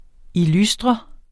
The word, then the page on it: illustre: iˈlusdʁʌ; iˈlysdʁʌ